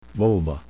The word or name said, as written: vulva